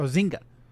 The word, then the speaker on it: Zynga: Zynga? Isn't that how that's pronounced? – Oh, Zynga. Zynga.